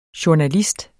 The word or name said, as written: JOURNALISTERNE